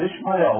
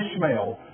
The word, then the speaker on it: Ishmael: And the prophecy about this child, Ishmael, or Ishmael, depending on how you want to pronounce it, is this.